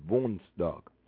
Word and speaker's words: woensdag: But you got W-O-E-N-S-D-A-G. Wunsdag. You got that foon, like foon, foon, foon. Wunsdag, like boom. Volkswagen, every time you see a W in front of the Volkswagen, it's actually pronounced V. So it's Wun, Wunsdag. Wunsdag. Wunsdag. Wunsdag, which is Wednesday. – Donnerdag. – All right, we're moving into Thursday. Donnerdag. Which is D-O-N-D-E-R-D-A-G, Donnerdag.